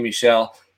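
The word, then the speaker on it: Michel: I've always said Michelle. – Michelle. Okay. So we're all right. So it's Michelle.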